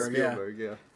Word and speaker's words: Spielberg: Spielberg.